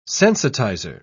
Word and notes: sensitizer: British English

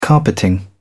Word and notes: carpeting: British English

